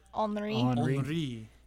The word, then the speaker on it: Henri: It's spelt like Henry, but it was... – Henri. – So that one... There was a big public information campaign on the right pronunciation of that.